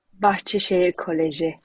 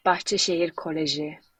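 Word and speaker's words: Bahçeşehir Koleji: Wie wird dieser Club ausgesprochen? – Bahçeşehir Kolleji. Hallo zusammen, by the way. Es heißt Bahçeşehir Kolleji.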